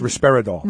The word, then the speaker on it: risperidone: Johnson & Johnson defended risperidone. Is that how you pronounced it? – Yeah, it's risperidone.